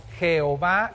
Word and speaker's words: Jehová: Entonces, esas mismas vocales puestas con estas consonantes, en algunos idiomas se pronuncia Jehová y en otros idiomas se pronuncia Yahvé, pero es exactamente el mismo Dios.